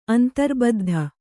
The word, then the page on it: ಅಂತರ್ಬದ್ಧ: Kannada